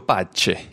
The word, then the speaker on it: Pace: Enrico Pace. Pace, c'est bien ça, parce qu'il est bien italien, donc le Pace.